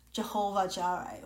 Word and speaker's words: Jehovah Jireh: This in Hebrew, it says Jehovah Jireh, or the J actually should be pronounced as Y.